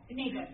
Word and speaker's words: Nabal: Nabal, thank you, that's how you pronounce it, yeah.